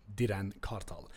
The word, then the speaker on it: Diren Kartal: But, yeah, I was born in North Middlesex Hospital, and my uncle named me Diren Kartal. That's how you say it in Turkish, Diren Kartal.